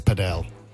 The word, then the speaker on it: padel: Yeah, but sorry, can I just stop you? It's padel. – What? – Many people pronounce it padel.